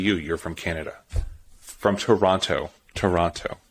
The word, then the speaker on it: Toronto: Without the T at the end. Toronto. – No T at the end. – Yeah, that's how you say it. It's so funny. A silent T.